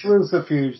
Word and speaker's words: Lucifuge: Yeah, it's Lucifuge.